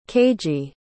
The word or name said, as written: keiji